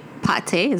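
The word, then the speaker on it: pâté: I literally just had a pate.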